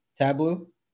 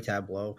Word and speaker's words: Tableau: So Salesforce, and I always mispronounce this name Tableau. Tableau. I knew it was gonna. I always sorry, Tableau.